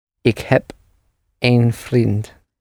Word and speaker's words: een: And the word for one is also spelled the same, E-N, but pronounced een, een, een.